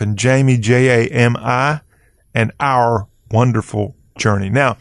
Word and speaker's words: Wanderful: And it comes to us from Our Wonderful Journey. And I said that right. Wonderful. W-A-N-D-E-R-F-U-L. As in, like, you go wander, but it's Wonderful Journey. And the Twitter account is Our Wonderful. O-U-R-W-A-N-D-E-R-F-U-L.